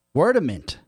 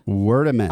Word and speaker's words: Wordament: Wordament? – Wordament. – How do you spell that? – Word-A-Ment. – Okay, Wordament. – M-E-N-T.